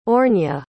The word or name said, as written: aurnia